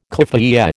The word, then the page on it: keffiyeh: American English